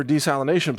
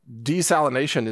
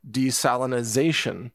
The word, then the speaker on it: desalination: And by the way, just as a quick note, desalination is the correct pronunciation of that term. I hear a lot of people say desalinization, which is they're adding an extra syllable. So just want to let you know that the correct way is desalination.